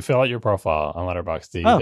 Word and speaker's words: Letterboxd: I could do this on Letterboxd. Which has a silent D. – It's not silent. You just said it at the end of the word. Letterboxd.